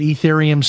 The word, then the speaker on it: Ethereum: And I pronounce it Ethereum. Some people pronounce it uh, a different way, um, but I, I call it Ethereum or Ether.